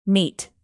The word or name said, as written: meet